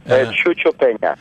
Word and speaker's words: Chucho: And I in your I guess really autobiography, you talk about you just mentioned the death of a poet you knew, Chucho, is that how you pronounce it? – Yeah, Chucho Peña. Yes.